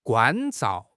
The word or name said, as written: 关照